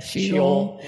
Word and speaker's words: Sheol: Sheol.